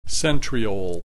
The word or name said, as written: centriole